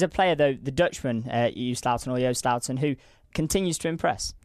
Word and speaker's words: Joost: I saw a few people say it rhymes with toast. – Yeah, so Joost or Joost.